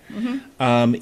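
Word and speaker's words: Giger: Geiger, I think it's pronounced H.R. – Geiger. – Geiger.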